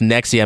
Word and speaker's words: NXIVM: It's like NXIVM. It's all like, it looks like kind of like Roman numerals, but it's I believe it's pronounced Nexium, and it's basically those celebrities.